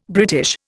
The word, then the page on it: British: Indian